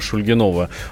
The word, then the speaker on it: Шульгинов: Вот мы вчера спорили в редакции, как правильно читается фамилия Шульгинова. Вот, нам Михаил Владимирович подсказал. – Да, Шульгинов, так и читается.